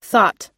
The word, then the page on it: thought: American English